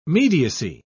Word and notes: mediacy: British English

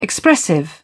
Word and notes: expressive: British English

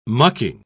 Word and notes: mucking: British English

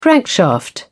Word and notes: crankshaft: British English

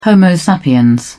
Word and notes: homo sapiens: British English

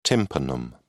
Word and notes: tympanum: British English

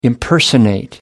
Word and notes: impersonate: American English

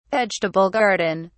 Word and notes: vegetable garden: British English

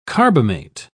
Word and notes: carbamate: British English

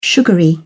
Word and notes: sugary: British English

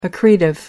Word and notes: accretive: American English

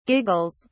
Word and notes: giggles: British English

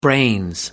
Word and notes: brains: British English

